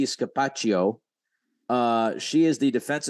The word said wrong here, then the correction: Scapicchio: I- will pronounce it Scapicchio. – There's an I.